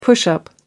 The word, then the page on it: push-up: American English